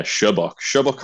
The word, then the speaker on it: Sherbok: Yeah, Shabok Holmes, just like the infamous, notorious detective.